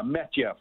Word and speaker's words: Matvei: Is it Matvei Petrov? Is that how you pronounce his name? – Yeah, Metyev, we call him.